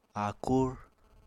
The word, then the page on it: áakúr: apatani